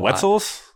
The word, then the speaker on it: Wetzel: The Wetzels. – The Wetzels, yeah. – And, like Wetzel's uh, Pretzels. Like like yeah, rhymes with pretzels.